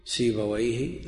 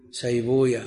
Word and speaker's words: سيبويه: زنجويه هكذا تنطق عند أهل اللغة، وكل ما ختم بويه، سيبويه، نفطويه، راهويه، أما أهل الحديث فيقول راهوية، زنجوية، سيبوية، وكل هذا على خلاف ما عليه أئمة اللغة، والمسألة المرد فيها إلى أهل اللغة.